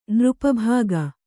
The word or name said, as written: ನೃಪಭಾಗ